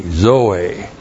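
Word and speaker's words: Zoe (ζωή): And then zoe. Z, long O, long E. Zoe.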